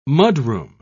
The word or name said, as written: mudroom